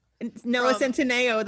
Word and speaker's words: Centineo: But then the guy, I don't know how to pronounce his last name. Noah Centineo? – Centineo, that's who I have.